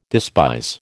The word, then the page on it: despise: American English